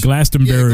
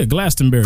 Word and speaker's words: Glastonbury: Glastonbury? – Yeah, that's how you say it.